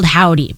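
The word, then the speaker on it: HOVVDY: They're called Howdy, but it's spelled weird. It's H-O-V-V-D-Y, because Howdy was probably already taken.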